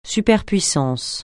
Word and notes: superpuissance: francais

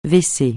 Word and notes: W-C: francais

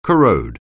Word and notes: corrode: anglais

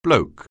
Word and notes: bloke: anglais

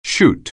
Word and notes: chute: anglais

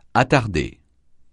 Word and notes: attardé: francais

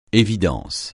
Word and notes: évidence: français

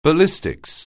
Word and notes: ballistics: anglais

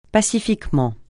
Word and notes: pacifiquement: francais

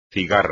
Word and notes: cigarra: espagnol

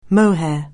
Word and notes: mohair: anglais

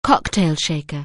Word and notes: cocktail shaker: anglais

